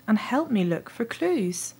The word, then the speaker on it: clues: Ma mère m'avait expliqué il y a longtemps comment prononcer le mot clues en anglais. Un peu comme rue, et pas du tout comme ruée, la prononciation française du Cluedo.